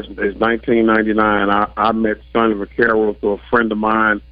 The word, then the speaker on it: Vaccaro: And I want to imagine, is it Vaccaro or Vaccaro? What's the correct pronunciation? – It's Sonny Vaccaro. – Vaccaro, okay. So tell us about your relationship with him.